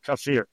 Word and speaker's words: kefir: My boss's name is Jim, and I always thought it was kefir, and he was always saying kefir. And I was like, Jim, you're wrong. Actually, I looked it up, and he was right. So I think it's kefir is how you say it.